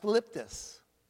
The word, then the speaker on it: thlipsis: And it's spelled T-H-L-I-P-S-I-S. It's pronounced phlipsis. Phlipsis.